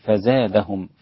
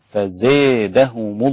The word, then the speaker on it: فزادهم: فابن ذكوان وحمزة قرأ بإمالة فزادهم، فيصير النطق بها فزادهم الله، بدل ما تجيب ألف فزا تنزل قليلا إلى الياء، فزادهم الله مرضا. هذه هي الإمالة، وهي إمالة الألف ناحية الياء قليلا. وإلا